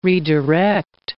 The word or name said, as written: redirect